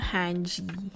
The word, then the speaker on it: Hange: So, ito, itago daw natin siya sa pangalang Hanji. Actually, maraming pronunciation daw. Yung character sa Attack on Titan. Kasi, pwede daw siya i-pronounce as Hans, as Hange. Pero, doon na lang tayo sa madali, Hanji.